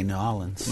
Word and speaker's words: New Orleans: How do you say that? New Orleans? – I say New Orleans.